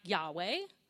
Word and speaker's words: Yahweh: But the God who calls themselves, I am who I am, pronounced something like Yahweh, will not take no for an answer.